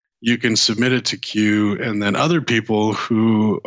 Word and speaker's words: Quuu: You know, one of the ones that I like right now is Q. You heard of Q? – No, what is it? – It's spelled Q-U-U-U.